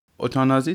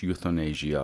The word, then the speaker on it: اوتانازی: اوتانازی تلفظ فرانسوی این واژه است. تلفظ انگلیسیش یوتانیزیا است.